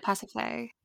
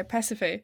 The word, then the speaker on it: Pasiphae: how do you pronounce her name? Pasiphae? – I said it, Pasiphae. – Yeah, Pasiphae.